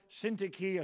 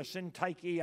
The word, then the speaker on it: Syntyche: So, Euodia, Euodia, Syntyche, or Syntyche, I think either one of those pronunciations fairly close.